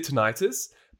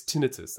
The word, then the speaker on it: tinnitus: No, seriously, like, because I've always said tinnitus, but I actually have an acquaintance who is an audiologist. And she just told me, she goes, no, it's tinnitus. That's actually how you say it.